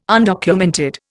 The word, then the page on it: undocumented: Indian